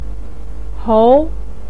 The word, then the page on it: hong: 2